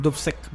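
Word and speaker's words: Dubček: de Alexander Dubek. Dubek, perdón. Dubsek.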